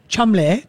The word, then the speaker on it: Cholmondeley: Of Shumley, but it's spelled like Chamandandali, you know, but it's pronounced Chumley. That's how you say it in a British way.